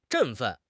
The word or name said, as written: 振奋